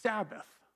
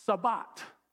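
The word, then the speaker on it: Sabbat (שבת): And that word is the word Sabbath. Now, in the Hebrew, the word is pronounced Sabbat. Sabbat.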